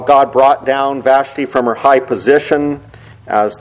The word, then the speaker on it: Vashti: By the way, I've looked up the pronunciation of that name, and I think last time I said Vashti, and that is what some say is the pronunciation, but then others say it's Vashti. Well, I think I grew up learning as Vashti, so I'm going to say Vashti in this sermon.